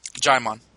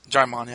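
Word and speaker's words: Djimon: Like, Jimon Hatsu from, is that how you say his name? – Jaimon. – Jaimon Hatsu? – Jaimon, yeah.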